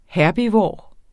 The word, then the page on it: herbivor: ˈhæɐ̯biˌvɒˀ; ˈhæɐ̯biˌvoˀɐ̯